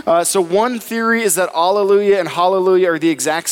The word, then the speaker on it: alleluia: Another theory is that it was alleluia for all of time, because the actual way you would pronounce it in Hebrew would be alleluia,